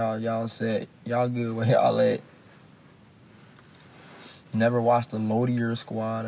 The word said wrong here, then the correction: Loiter Squad: How do you say that shit? – The Loiter Squad? – The Loiter Squad.